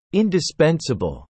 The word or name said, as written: indispensable